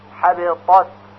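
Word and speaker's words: حبطت: پس این طور تلفظ میکنیم، حبیطات اول با تا و بعد با ت، حبیطات.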